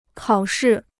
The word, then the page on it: 考试: kǎo shì